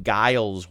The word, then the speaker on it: Guile: And I think there was a character, if I'm reading this correctly, named Guile, G-U-I-L-E, Guile, okay. – right? – Guile rhymes with Kyle.